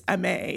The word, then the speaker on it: Aimée: So my name is actually the verb. It's Amay. It's spelled A-I-M-E-R in French.